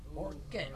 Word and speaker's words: Orkut: Is this Orchid like a flower, or how you spell that? – O-R-K-U-T. Orchid.